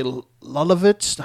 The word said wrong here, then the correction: Leibovitz: How do you say that? Leibovitz. – Say that again. Leibovitz. See, that sounds like a sneeze.